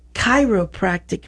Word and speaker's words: chiropractic: He is a doctor of chiropractic medicine. – So, that is correct. Correct way to say it. – Yes, and he said Cairo like Egypt.